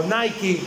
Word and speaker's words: Nike: Nike o Nike, como se pronuncia.